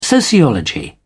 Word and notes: sociology: British English

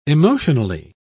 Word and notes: emotionally: British English

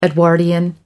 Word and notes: Edwardian: American English

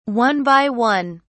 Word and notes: one by one: British English

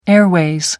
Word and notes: airways: American English

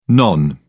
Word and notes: non: British English

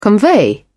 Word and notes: convey: British English

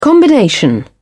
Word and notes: combination: British English

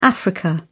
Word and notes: africa: British English

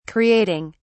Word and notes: creating: British English

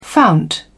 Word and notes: fount: British English

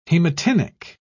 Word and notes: hematinic: British English

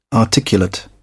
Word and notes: articulate: British English

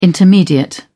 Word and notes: intermediate: British English

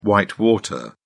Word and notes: whitewater: British English

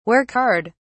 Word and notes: work hard: British English

